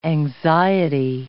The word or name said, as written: anxiety